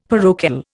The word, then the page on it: parochial: Indian